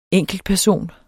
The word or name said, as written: ENKELTPERSON